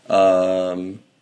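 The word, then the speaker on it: Hashirama: Hashirami? Is that how you pronounce it? – That sounds right.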